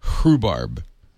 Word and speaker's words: rhubarb: R-H-U. Now, rhubarb is R-H-U, isn't it? The H is silent. – Yeah, it's like rheumatism. – Rhubarb.